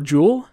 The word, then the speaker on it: Juul: How do you say the little smoke thing? – Vape? – No, the brand that kind of got in hot water. – Oh, Juul? – Yeah. Okay. – Yeah, it's J-U-L. J-U-U-L.